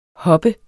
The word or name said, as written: hoppe